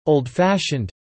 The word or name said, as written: old-fashioned